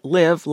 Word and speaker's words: LIV: That's L-I-V, pronounced, as we said, live like live large, which the tour did, offering eye-popping paychecks to attract players from the PGA.